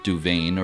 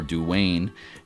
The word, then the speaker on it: Dubhan: That's spelled D-U-B-H-A-N and pronounced Duvain or Duwain, and the headland became known as Rhyn Duvain or Duvain's Headland.